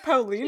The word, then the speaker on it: Paolini: But does Paloni know that, like, if you go past probably about... Paloni. Does Paloni... Now, how do I say his name? Paloni? – Isn't it Paolini? Yeah, Paolini.